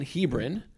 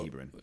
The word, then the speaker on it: Hebron: After the biblical Chevron, Hebron. – Yeah. – Which I've checked with you. It's a correct pronunciation.